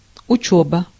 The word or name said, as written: drill